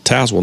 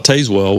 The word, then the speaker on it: Tazewell: Tazewell, not Tazewell, which is exactly how I would have said it.